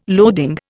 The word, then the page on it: loading: Indian